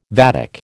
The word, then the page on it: vatic: American English